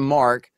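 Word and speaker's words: marque: But it would, yes, essentially a letter of Mark. – Mark, Is, well, it's spelled funny, so it's not your fault. – It's French. But, it's French.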